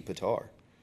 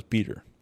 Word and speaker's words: Petar: I also realized that I think it might actually be Petar. – I thought it was Peter. – I mean, it's P-E-T-A-R. – I know how it's spelled, but I mean, like, I always thought he just said, like, Petar.